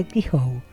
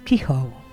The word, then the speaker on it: Kehoe: Se pronuncia Kehoe. Claro. Yo, por si acaso, como no conozco la pronunciación de algunos apellidos, pretendo leerlos tal cual están escritos. Kehoe.